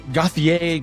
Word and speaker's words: Gauthier: And uh, we've got the wonderful Rob Gauthier. Uh, Gothier, Gothier, you know, there's different pronunciations. What do you prefer, Rob? It doesn't matter to me. I've, uh, my family, I grew up with Gothier, but um, our family up north in uh, the UP of Michigan also Gothier.